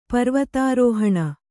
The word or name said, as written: ಪರ್ವತಾರೋಹಣ